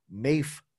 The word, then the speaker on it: maafe: Like apart, well, the Trinidadian double is pretty special, but the fufu nudi with mafe sauce, and mafe is spelled MAAFE, mafe sauce, and nudi is spelled GNUDI.